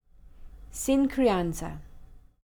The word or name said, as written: sin crianza